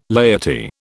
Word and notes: laity: British English

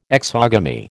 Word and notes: exogamy: American English

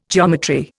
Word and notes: geometry: Indian

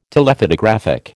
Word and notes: Telephotographic: American English

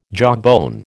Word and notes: jawbone: American English

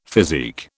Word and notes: physique: British English

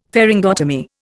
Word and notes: pharyngotomy: Indian

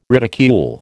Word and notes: ridicule: American English

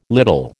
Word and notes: little: American English

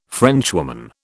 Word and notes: Frenchwoman: British English

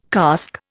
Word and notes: cask: Indian

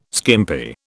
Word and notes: skimpy: British English

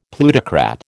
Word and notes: plutocrat: American English

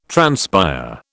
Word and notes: transpire: British English

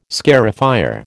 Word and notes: scarifier: American English

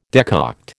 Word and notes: decoct: American English

